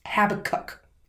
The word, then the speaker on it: Abacuck: Habakkuk, not Habicus. – Habakkuk. – Habakkuk Prickett. And they spell it A-B-A-C-U-C-K.